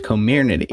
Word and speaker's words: Comirnaty: The official name of the Pfizer COVID vaccine is Comirnaty. – Can you just spell that for me? Comirnaty? – Comirnaty. – C-O-M-I-R-N-A-T-Y.